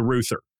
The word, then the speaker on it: Reuther: It's spelled like R-E-U-T-H-E-R, and it's pronounced Reuther.